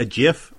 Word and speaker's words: GIF: Through a set of circumstances too complicated to relate here, I happened to run into a GIF of Mark McGwire and Kirk Gibson almost getting in a fight, and I looked up. – It's a hard G. We're a hard G podcast.